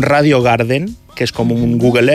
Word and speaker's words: Radio Garden: És Radio Garden. – Correcte.